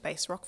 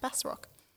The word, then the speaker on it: Bass Rock: I've been saying it Bass Rock for so long, but I'm listening to the audiobook now and it's apparently pronounced Bass Rock. My bad.